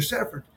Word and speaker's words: Severn: My name is Mr. Severn. If you have a hard time pronouncing Severn, you can call me Mr. S or Coach.